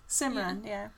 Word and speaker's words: Simran: But then, I love it when, her mom finds out that Simran, am I saying, wait, let me I'm saying her name right. – Yeah, Simran.